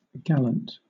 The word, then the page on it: gallant: British English